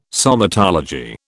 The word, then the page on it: somatology: British English